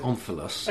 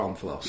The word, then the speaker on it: omphalos: I don't know how to pronounce it. How would you pronounce it? – I pronounce it omphalos. – Okay, well, that's good. I've got that. – That'll do for me. I don't claim to be an educated man who knows the correct pronunciations of things. Let's go for omphalos.